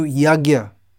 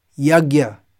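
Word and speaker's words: yajña: Sacrifice is generally the term used to translate the Sanskrit yajna or yajna, as some people pronounce it, which according to some is the correct pronunciation.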